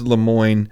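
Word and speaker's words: Lemoine: I'm saying Lemoyne. I think it's Lemoyne. – Yeah, that should be right.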